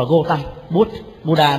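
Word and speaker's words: Gautama: Ở Ấn Độ họ phiên âm là Gotam, bởi vì cái chữ A là nguyên âm đặng cuối câu á, thì không có đọc, đọc là Gotam Buddha, đọc là Bút, Gotam Bút.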